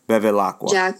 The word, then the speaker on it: Bevilacqua: I feel like I said that completely wrong. – No, though. You're pretty close. I happen to. I think know how to pronounce it because I'm Italian. Okay. So I think it's Giacomo Bevilacqua.